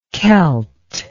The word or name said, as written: kelt